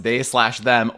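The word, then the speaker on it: They/Them: For the virgins, if you don't know, there's this Peacock original that, you know, the title is They Them, but it's supposed to be pronounced They Slash Them, or as Tomas Matos Stupid.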